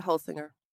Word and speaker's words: Holsinger: Is it Holsinger? – Yep, that's right. Holsinger.